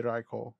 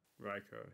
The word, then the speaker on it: Raikou: I think it is because the Japanese is Raikou. Yeah, it would be Raikou. – Raikou, yeah. I like Raikou.